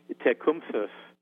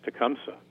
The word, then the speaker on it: Tecumseh: And he, in fact, wrote it out in a memoir phonetically, and he said that the Shawnees pronounce his name, and I'll try to do it as much justice as I can, Tecumseh, with a, kind of a TH sound at the end. He suggested that pronunciation was too difficult for most non-Native Americans, and who just simplified it as Tecumseh, just kind of lazily.